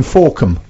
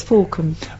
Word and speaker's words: Fawkham: Is it F-A-L-K-H-A-M? – F-A-W-K-H-A-M. – Yeah. – Fork ham. – That's the way you're saying it, I knew it. It's like handbag, you see. My son spelt handbag H-A-M-B-A-G because people like you said it wrong.